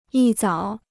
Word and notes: 一早: yī zǎo

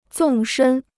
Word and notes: 纵深: zòng shēn

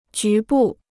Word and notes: 局部: jú bù

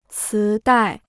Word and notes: 磁带: cí dài

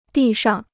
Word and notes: 地上: dì shang

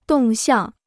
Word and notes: 动向: dòng xiàng

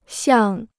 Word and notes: 像: xiàng

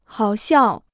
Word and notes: 好笑: hǎo xiào

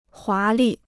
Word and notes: 华丽: huá lì

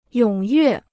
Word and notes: 踊跃: yǒng yuè

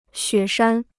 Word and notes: 雪山: xuě shān

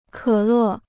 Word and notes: 可乐: kě lè

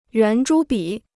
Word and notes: 圆珠笔: yuán zhū bǐ

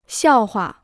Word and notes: 笑话: xiào hua

